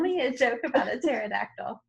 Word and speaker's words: pterodactyl: It's something about pterodactyl, and it says something like the punchline's like, because it has pee in it or something. Because the pee is silent.